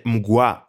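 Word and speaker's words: Mgła: Όλο αυτό το story ξεκίνησε από το live ενό ε, συγκροτήματο, τον Μγκλά, έτσι γράφεται, προφέρεται Μγκουά από ό,τι έχω ακούσει. Εγώ προτιμώ να του αποκαλώ Μίγκλα, ή μου ακούγεται πιο ωραίο, Μίγκλα, το οποίο είναι ένα συγκρότημα από την Πολωνία.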